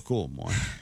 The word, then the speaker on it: Como: Beh, io dico Como.